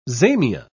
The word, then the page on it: zamia: British English